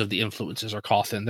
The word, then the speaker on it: Cawthon: I think it's Cawthon. That's what I always say. – Yeah, I do too. – Yeah, it's Cawthon. I mean